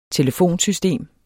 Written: telefonsystem

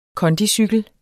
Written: kondicykel

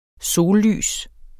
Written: sollys

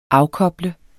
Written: afkoble

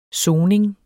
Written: soning

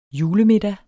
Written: julemiddag

